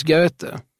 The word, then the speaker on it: Goethe: Nej, nej, det uttalas Goethe.